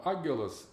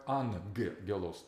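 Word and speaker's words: ангелос (ἄγγελος): И «агелос» читается вот этот две согласных подряд, два «г», читается как «ангелос», то есть «н».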